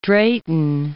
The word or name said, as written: Drayton